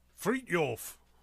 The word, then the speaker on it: Fridtjof: Fridtjof Nansen. So Fridtjof is his first name. Nansen. Nansen. That one's a little bit easier. – Fridtjof.